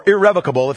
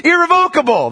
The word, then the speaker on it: irrevocable: Or irrevocable, if you pronounce it properly. Irrevocable, though, is the northern way of pronouncing it, right? It is irrevocable.